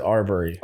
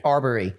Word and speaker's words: Arbery: I would pronounce it Arbery. – Arbery.